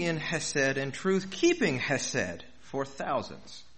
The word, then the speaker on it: chesed: That word is hesed, C-H-E-S-E-D, hesed. The C is silent. I don't know why.